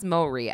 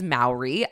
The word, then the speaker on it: Mowry: It's pronounced Mowry, actually. You learn something new every day. I always said his name is Mowry.